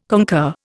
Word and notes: concur: Indian

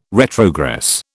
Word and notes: retrogress: British English

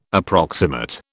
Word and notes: approximate: British English

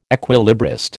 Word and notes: equilibrist: American English

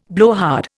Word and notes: blowhard: Indian

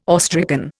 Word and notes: awestricken: Indian

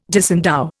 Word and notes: disendow: Indian

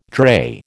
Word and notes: drey: American English

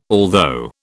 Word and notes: although: British English

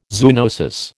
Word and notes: zoonosis: American English

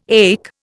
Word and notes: ache: Indian

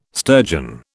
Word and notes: sturgeon: British English

